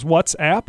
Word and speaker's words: WhatsApp: A lot of people do use and abuse the social networks, and that really informed Facebook's decision to purchase the WhatsApp, I don't even know how you pronounce this, WhatsApp, I guess, company. – What's that? What's that? Like the old Budweiser company. What's that? – Exactly. No, I think that's how you pronounce it.